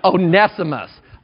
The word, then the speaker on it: Onesimus: This is Paul's plea for Onesimus. I always used to call him Onesimus. It's Onesimus. O-N-E-S-I-M-U-S. Onesimus, okay?